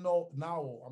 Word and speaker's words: Nowell: They got Nowell, the small forward who plays great defense. He actually spent a lot of time on Kyrie in the second half when Kyrie started. Jalen Nowell. I'm not sure if I'm pronouncing his name correctly. – Yep. – N-O-W-E-L-L.